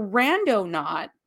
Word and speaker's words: Randonaut: And it's spelled R-A-N-D-O and then Naut, N-A-U-T, like astronaut. So Randonaut.